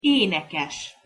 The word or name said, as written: enekes